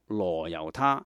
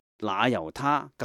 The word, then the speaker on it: 那由他: 那由他，传统读作罗由他，但是梵文是 Nayutta，所以应该读成那由他更为贴切的。